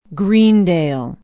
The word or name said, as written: greendale